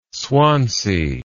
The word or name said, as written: swansea